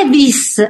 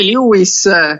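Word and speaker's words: Levi's: Eh sì, ma sì, ma non si legge, ma non si legge Lewis perché è tedesco.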